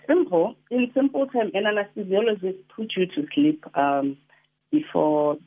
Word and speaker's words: anesthesiologist: You are, how do you say this name? Anesthetist. – Anesthesiologist. – Looks like there's anesthesia somewhere there in the middle.